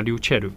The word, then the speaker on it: ryuchell: 好，那在日文发音里面会直接叫他 ryuchell 啊，ryuchell。